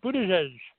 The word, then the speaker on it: Buttigieg: Mr. Pete Buttigieg. Any, Buttigieg.